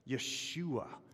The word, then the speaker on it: Yeshua: Yeshua is what Mary and Yosef would have pronounced.